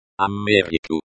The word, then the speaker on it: Америку: Америку. Америку. This is the noun, America. It is in the accusative case, because it follows the preposition. The stress is on the second syllable.